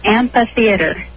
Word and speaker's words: amphitheatre: Do you pronounce this amphitheater, amphitheater, or amphitheater? – It's amphitheater. – Amphitheater. – No L. – Uh-huh. – How do you spell it? – A-M-P-H-I-T-H-E-A-T-R-E.